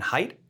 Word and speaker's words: Haidt: I know I have a favorite writer, Jonathan Haidt. Is that how you pronounce it? – I think so.